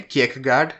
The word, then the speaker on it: Kierkegaard: Like, if somebody does not know Freud, if somebody hasn't read like, Kierkegaard, am I saying it right? – Yeah. – Kierkegaard? – Yeah.